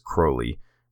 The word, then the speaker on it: Crowley: And I used to call it Crowley, but long story short, a more appropriate pronunciation I've heard is Crowley, rhyming with holy or the unholy.